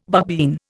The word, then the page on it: buckbean: Indian